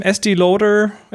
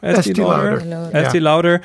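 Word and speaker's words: Estée Lauder: Estee Lauder. Estee Lauder.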